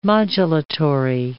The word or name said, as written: modulatory